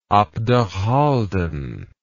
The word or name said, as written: abderhalden